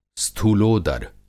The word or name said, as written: स्थूलोदर